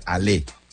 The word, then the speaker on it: Allez: Here we go Steelers, es Allez Steelers. A-L-L-E-Z. Se pronuncia Ale. Ale, Steelers.